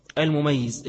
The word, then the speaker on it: المميز: المميز اكسرها عشان تنطق صح، مش المميز، المميز ده وصف، لكن المميز يجب على ولي المميز المطيق للصوم أو المطيق للصوم أمره به وضربه عليه ليعتاده، قياسا على الصلاة، قياسا على الصلاة.